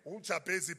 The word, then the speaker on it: Jabez: Jabez. Angazi uJabez or uJabez, depending which school you are coming from, but it is spelled J-A-B-E-Z. uJabez, pronouncing it in clear.